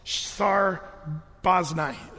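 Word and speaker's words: Shethar Bozenai: It's the Shethar part is actually one syllable, really. It's like Shthar. Shthar Baznai is his name.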